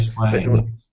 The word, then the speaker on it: Square Enix: And it's Square Enix. I think that's how you pronounce it, isn't it? Square Enix. – Yeah, Square Enix.